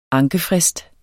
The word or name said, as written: ankefrist